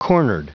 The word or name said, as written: cornered